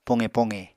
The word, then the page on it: poqe-poqe: Apatani